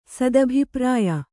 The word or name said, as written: ಸದಭಿಪ್ರಾಯ